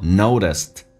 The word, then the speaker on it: noticed: T 在美式发音当中要被浊化叫做 noticed, 最后一个 T 是不发音，在音标当中 noticed, T 是不发音的，因为后面是一个辅音开头，就把它省略，叫做 notice that, notice that, that 的 T 不发音，但气流稍微的阻塞一点点，因为前面是一个元音，叫 notice that.